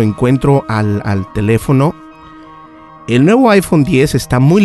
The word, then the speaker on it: iPhone X: De hecho, no se dice iPhone X, es iPhone X o iPhone 10.